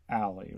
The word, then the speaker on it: Aly: And then there's Allie, right? – Yeah. I'd never seen the name spelled like that before. It's spelled A-L-Y.